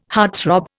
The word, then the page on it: heartthrob: Indian